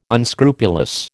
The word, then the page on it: unscrupulous: American English